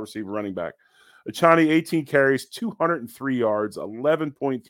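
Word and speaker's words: Achane: Okay, you got um, uh, how how do you pronounce my man's name? Akane, Achani. – Um, Achani, the receiver for the Miami Dolphins.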